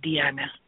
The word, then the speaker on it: Diana: Well, because it's Spanish, I spell it like Diana, but I say it Deanna. – Oh, okay. So it's D-I-A-N-A? – Right.